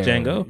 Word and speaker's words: Django: Yeah, Django. – Yeah. – The D is silent.